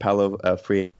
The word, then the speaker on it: Paulo Freire: It's a really great kind of fringy subfield of psychology that's based off of Paulo Freire, is how you pronounce his name.